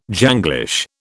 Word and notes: Janglish: British English

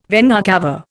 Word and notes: vena cava: Indian

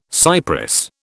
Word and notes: cypress: British English

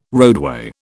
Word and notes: roadway: British English

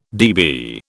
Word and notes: dB: British English

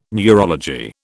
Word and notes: neurology: British English

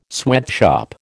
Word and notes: sweatshop: American English

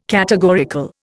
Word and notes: categorical: Indian

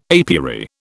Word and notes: apiary: British English

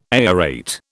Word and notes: aerate: British English

